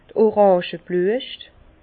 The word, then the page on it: d' Orangeblüescht: Bas Rhin; Herrlisheim